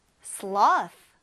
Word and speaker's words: sloth: sloth. 나무늘보예요. What kind of animal is it? sloth. th 발음, 우리 th 하는 이 혀를 또 윗니 아래 사이에 놓고 바람을 내뱉어 주세요. th 해서 sloth라고 발음하면 됩니다.